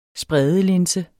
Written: spredelinse

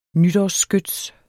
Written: nytårsskyts

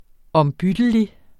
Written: ombyttelig

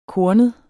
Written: kornet

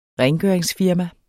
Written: rengøringsfirma

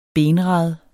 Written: BENRADENE

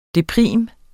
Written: deprim

